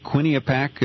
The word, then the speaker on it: Quinnipiac: Quinnipiac, I think is how it's pronounced.